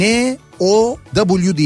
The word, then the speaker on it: Now: Yani N O W diye yazılıyor. Evet şimdi anlamında Evet gibi. şimdi anlamında Now diye e, okunuyor.